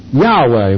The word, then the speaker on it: Yahweh: E-O-A. That's the way we get Jehovah. Well, it's Yahweh, mostly. This is pronounced like a Y.